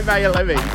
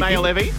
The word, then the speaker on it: Maor Levi: Did I pronounce that correctly? – I love the way you say Maor Levi. – Is it Maor Levi? – Yeah, it is.